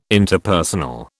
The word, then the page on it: Interpersonal: British English